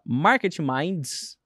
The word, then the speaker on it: Market Minds: Já acompanhei vários deles, que chama Market Minds, né? Falei certo, eu acho. – Falou Falei? certíssimo.